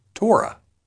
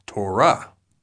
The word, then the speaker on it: Torah: For example, when we say this Hebrew word, many often say Torah. However, in Hebrew, the closed syllable comes at the end, so it is actually pronounced Torah.